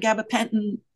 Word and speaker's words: gabapentin: But when you get, or serotonin syndrome, one of my friends was on gabapentin.